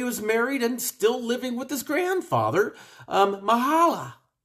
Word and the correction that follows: Mahalalel: I think I'm pronouncing that right. Mahalel. We'll call him Mahalel. It's spelled M-A-H-A-L-A-L-E-L. Yeah, we're going to go with Mahalel.